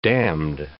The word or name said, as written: damned